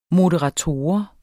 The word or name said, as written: moderator